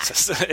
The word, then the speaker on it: sestina: We've had haiku threads and limic threads, but someone made a post the other day about Sistinas. or maybe it's pronounced differently and I am showing my lack of familiarity again, but how else would you pronounce it? Sestina? – Sestina. – It's probably Sestina, yeah.